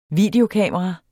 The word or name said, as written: videokamera